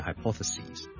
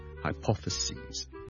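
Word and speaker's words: hypotheses: So hypothesis， hypothesis plural，我哋係 S-I-S 轉咗做 S-E-S，就變咗 hypothesis， hypothesis， hypothesis，記住個發音，OK？由 sis 轉咗做 sees，你當好似係 C-S-E-A 或 S-E-E 嗰個 C，跟住再加多個 S， so singular hypothesis， plural hypothesis， singular crisis， plural crisis。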